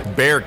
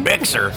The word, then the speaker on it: Bexar: And if you say, you know, Behar or Bexar County, you get laughed out because everybody calls it Bear County. Bear County. But I guess this bowling alley just says Bexar, which I guess is like the most Texified or whiteified pronunciation of that. Bexar.